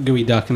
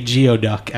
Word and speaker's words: geoduck: We were just talking about gooey duck, and I was also excited that you pronounced it prior to me, because I usually say geoduck at first, just phonetically seeing it spelled out.